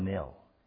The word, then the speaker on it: knell: It was a knell. Is it the way we pronounce it? K-N-E-L-L, a knell? Knell.